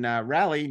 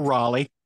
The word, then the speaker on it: Raleigh: Then the following week, July 27th to the 30th, we're going to be in Raleigh, North Carolina. Did I say that right? – Or Raleigh. – Oh, okay. One day I'll get it right. – We're going to rally to pronounce Raleigh correctly.